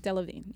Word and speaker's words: Delevingne: Delevingne, yes.